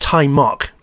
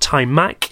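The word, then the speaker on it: Taimak: And it is pronounced Tai Mok, by the way, not Tai Mak.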